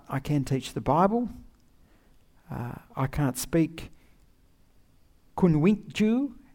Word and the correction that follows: Kunwinjku: How do you say it, Haley? Kunwinjku.